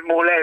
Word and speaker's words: Mulè: Con l'accento sulla E, non esistono in Lombardia nomi con l'accento sulla E, esiste Brambilla, mulè è un terrore.